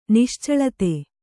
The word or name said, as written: ನಿಶ್ಚಳಿತ